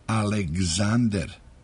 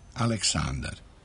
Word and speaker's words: Aleksander: Bliže izvornom izgovoru bilo bi Aleksander, ne Aleksandar.